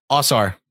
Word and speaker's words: Ausar: Ausar.